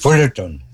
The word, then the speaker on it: Fullerton: Fullerton 90, sí lo digo bien, ¿verdad? Fullerton, así es. – Fullerton, sí, sí.